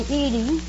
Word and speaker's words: Edie: Oh, do they call you Edith or Edith? – They usually call me Edie. – Edie? – Mm-hmm. I'd like to have you call me Edie, if you don't mind.